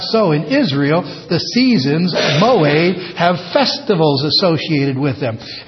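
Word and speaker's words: moed (מועד): That word seasons is important because it's the Hebrew moed, M-O, long E, pronounced A in Hebrew, moed.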